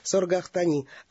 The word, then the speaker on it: Саргахтани: Мать со сложным именем Саргахтани, тут, наверное, двойное надо ударение, Саргахтани, судя по всему, пишут о ней, что она была замечательная женщина.